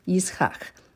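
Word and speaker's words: Yitzchak (יצחק): That's well of the covenant, and offered sacrifices to the God or Elohim of his father Isaac, or Yitzchak. Remember, that's how to pronounce it in Hebrew, Yitzchak, laughter.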